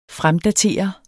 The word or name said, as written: fremdatere